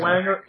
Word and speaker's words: Wenger: Uh, do you pronounce his name Wanger? Uh, Arsène Wenger.